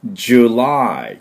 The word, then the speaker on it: July: July って言います。ね。焼肉のジュの音に、 lie, I っていう音ですね。